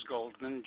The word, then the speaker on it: Goldman: Last name is Goldman, G-O-L-D-M-A-N.